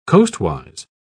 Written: coastwise

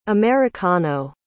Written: americano

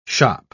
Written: shop

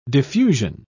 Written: diffusion

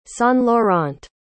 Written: saint-laurent

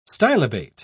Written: stylobate

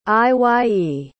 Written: Iye

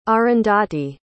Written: Arundhati

